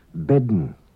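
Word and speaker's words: ben: An unwritten D is pronounced before N in some Manx words. Bedden, woman. Leaden, full.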